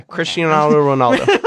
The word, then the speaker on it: Cristiano Ronaldo: Is that how you say his name? – Yeah, Cristiano Ronaldo.